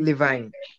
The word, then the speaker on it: Levine: Mas, falando agora também de algumas outras despedidas, é, rapidinho, para eu poder passar aqui rapidinho, é a Living Family. A, peraí, eu tô falando certo? Living Family Racing. Levine. É,